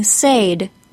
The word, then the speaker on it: said: Here are all four say and saying with a long A says, and said with a short E.